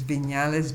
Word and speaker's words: Viñales: which means Viñales Valley. Viñales is spelled V as in victory, I, N, that's the N with the little mm-hmm. dito on top of it, A-L-E-S, Valley of Viñales.